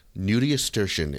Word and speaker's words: nudiustertian: Nudiastrition. – Is it like nudiastrition? – No. – Like a pediatrician, but for nude people? – Nope. Nudiestertian. It is spelled N-U-D-I-U-S-T-E-R-T-I-A-N.